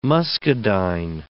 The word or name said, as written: muscadine